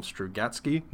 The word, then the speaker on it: Strugatsky: I will attempt to pronounce their last name, Strugatsky.